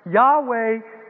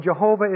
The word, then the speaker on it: Yahweh: Yahweh is the Hebrew pronunciation of the word, and Jehovah is the anglicized.